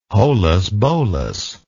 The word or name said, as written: holus bolus